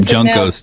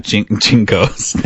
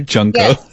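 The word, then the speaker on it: junco: There were some junkos, too, which are not jinkos. They are junkos.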